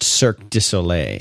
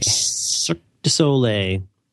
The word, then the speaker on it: Cirque du Soleil: It's pronounced Cirque du Soleil. – Cirque du Soleil.